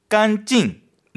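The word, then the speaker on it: 깐찡 (干净): 깐찡. 네, 일성사성 깐찡입니다.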